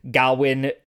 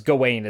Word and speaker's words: Gawain: And so in some lines, Gawain is the pronunciation to make the rhyme scheme work. It's all alliteration, so it's mostly just the meter. Okay. And in others, Gawain is the one to use.